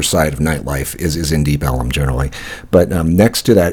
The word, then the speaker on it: Deep Ellum: they kind of mispronounced for many years, Elm, E-L-M, with Southern accent, to the point so much that they've just kind of added another L-U-M, so it's now Deep Ellum with this area.